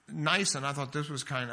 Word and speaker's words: Nisan: It's not Nissan, that's the car. It's Nisan.